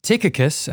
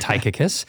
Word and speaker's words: Tychicus: Tychicus, as I'm told that it's pronounced, not Tychicus, will tell you all about my activities.